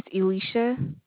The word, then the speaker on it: Elisha: My name is Elisha. – How do you spell that? – E-L-I-S-H-A.